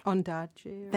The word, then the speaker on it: Ondaatje: Ondaatje.